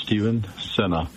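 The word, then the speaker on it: Steven Sena: Hello, my name is Stephen Senna. It's spelled S-T-E-V-E-N, and my last name is spelled S-E-N-A.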